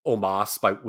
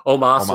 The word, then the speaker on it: Omos: What's the accepted pronunciation now? Is it almost? – I think it's Omos. – Omos.